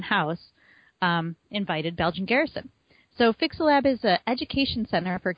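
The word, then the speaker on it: Fyxxilab: So next up, on August 23rd, Belgian Garrison was requested by Fixalab, which I'm assuming that's how it's pronounced. You folks can't see this, but it's F-Y-X-X-I-L-A-B. L-A-B. – L-A-B. – I think I got the lab part. – Fixalab. It's Fixalab.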